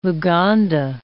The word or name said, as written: Luganda